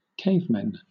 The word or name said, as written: cavemen